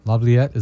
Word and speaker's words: Laviolette: Laviolette, is that am I saying his name right? – Mm-hmm.